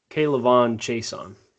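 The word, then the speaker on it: K'Lavon Chaisson: How about a chess piece like Clavon Chaisson? Is that how you say it? Edge? – Clavon Chaisson. – Yeah, okay.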